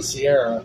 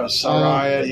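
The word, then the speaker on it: Saraya: I kind of think eventually they want to put the belt on Sierra or Ciara. – Yeah. – How you pronounce it? – Yeah, Soraya.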